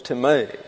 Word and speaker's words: timē: T-I-M-E. It looks like time, but for it to be time, this would have to be an epsilon, and it's not. It's an eta, and it's pronounced like an A in the English, and therefore it's timē, and means the showing of reverence to another.